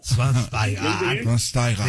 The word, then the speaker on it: Schweinsteiger: Después de 37 años de vida, ya sé cómo se pronuncia. Bastian Schweinsteiger. – Schweinsteiger. Sí, muy bien.